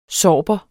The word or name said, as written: sorber